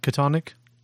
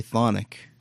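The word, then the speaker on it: Chthonic: How do you say that? – Thonic. – Not Katonic? – I've always heard people say Thonic. And the I assume C-H-A. They know. I assume they know more than I do. – So the CH is silent? – Yeah.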